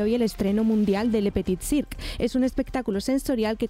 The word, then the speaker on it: Le Petit Cirque: Le Petit Cirque. Hay que hablar así como con la boca medio cerrada. y con la nariz por arriba.